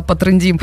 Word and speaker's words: потрендим: Небольшой перерыв и еще немножечко потрендим. Ударение на Е, друзья.